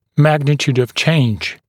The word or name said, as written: magnitude of change